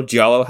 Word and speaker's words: giallo: Giallo? How the fuck do you pronounce that word? – It's giallo. – Okay. – Why did I say that like I'm fucking Italian? It's just giallo.